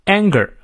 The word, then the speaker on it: anger: Anger 梅花 a 念到位啊，它嘴型呢也比较开啊。